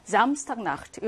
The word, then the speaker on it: Samstagnacht: 강세는 당연히 앞에 있는 명사에 오겠지. Samstagnacht 이렇게 발음을 합니다.